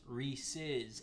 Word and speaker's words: Reese's: It's Reese's, because it was originally founded by a man with the last name Reese. And you put an apostrophe S at the end of it. So it's pronounced Reese's. It's his chocolate, peanut butter chocolate. – Reese's. – Reese's, not Reese's.